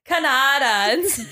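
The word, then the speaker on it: Cañada: It is spelled like Canada, yeah. But there's a little accent over the A Canada. Or the N. So it's Canada. Canada.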